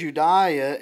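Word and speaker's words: Euodia: If you look at me in Philippians chapter 4, verse 2, it says, I urge Eudaia and I urge Syntyche. That's actually, I think, how you pronounce it in the Greek. It doesn't look that way.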